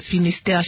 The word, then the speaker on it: Finistère: vous êtes directeur du cabinet du préfet de Finistère. J'espère que je prononce bien Finistère. – Tout à fait.